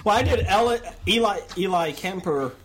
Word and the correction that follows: Ellie Kemper: Ellie Kemper.